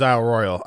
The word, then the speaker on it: Isle Royale: So, but yeah, the pronunciation is Isle Royale. I'd like me and Joe and I were calling it Isle Royale, like, you know, Battle Royale, but, that's like a mixture of two different types of pronunciations.